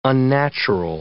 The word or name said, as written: unnatural